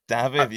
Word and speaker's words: Dafydd: Dafydd, yes.